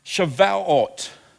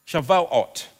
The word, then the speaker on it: Shavuot: And they called it, I'll try and pronounce it right, Shavuot. Shavuot.